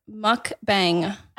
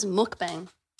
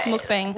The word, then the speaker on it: mukbang: This is the word mukbang. – I thought it was mukbang. – Either pronunciation works. Mukbang.